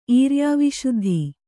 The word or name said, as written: ಈರ್ಯಾವಿಶುದ್ಧಿ